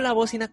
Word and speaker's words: Bócina: Pero bueno, Recuerda que nos puedes seguir en Facebook y en YouTube como La Bocina con acento en la O y también escríbelo con la letra C, C de casa, por favor, que no se te olvide.